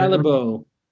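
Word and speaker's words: Callebaut: How do you pronounce Calbo? I studied French, but Calbo.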